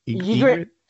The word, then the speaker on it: Ygritte: Ygritte.